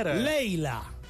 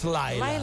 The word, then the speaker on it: Leila: Leila, not Laila. – Leila, like the Eric Clapton song. – Laila is Eric Clapton, right? – Yes. – But my song is Leila.